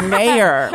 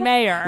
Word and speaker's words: Mayer: It's my last name is Mayer. – It's Mayer. – Oh, like John Mayer.